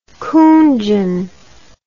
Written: coonjin

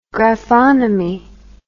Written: graphonomy

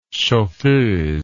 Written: chauffeuse

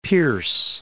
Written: Fort Pierce

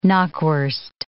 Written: knockwurst

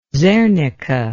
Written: zernike

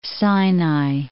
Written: Sinai

